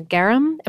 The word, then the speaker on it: garum: You also were posting on some kind of social media the other day about a garum.